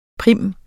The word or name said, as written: prim